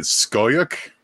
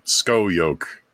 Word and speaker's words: Scoyoc: I've been saying it Skoyuk. – Van Skoyuk. – It's spelled S-C-O-Y-O-C. Skoyuk.